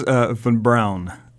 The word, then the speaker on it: von Braun: It is von Braun. Braun. – And just to clarify, I defer to my Marshall Space Flight Center historian. – So, because you call the von Braun Center and it's said wrong